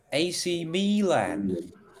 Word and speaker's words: Milan: AC Milan.